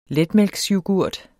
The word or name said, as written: letmælksyoghurt